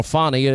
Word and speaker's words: Fani: Okay, well, we've got Fannie Willis. Fannie Willis, or Fani, I guess is how you, the proper pronunciation.